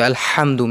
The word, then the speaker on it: Alhamdu: So Alhamdu, make the ha clear, not ha.